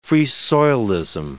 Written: Free-Soilism

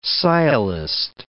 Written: sciolist

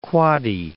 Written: West Quoddy Head

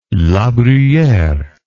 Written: labruyere

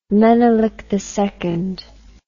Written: menelik ii

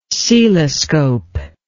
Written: coeloscope

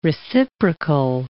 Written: reciprocal

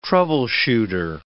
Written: troubleshooter

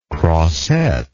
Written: crosette

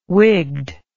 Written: wigged

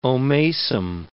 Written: omasum

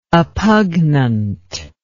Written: oppugnant

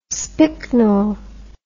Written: spicknel

